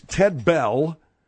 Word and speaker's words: Ted Bell: It's Ted Bell. – Well, his name is Ted Bell, though, right? – His name's Ted Bell, yeah. – Why do you spell it? – B-E-L-L. – But instead of just spell B-I-L-L. – No, it ain't Ted Bill, Ted Bell.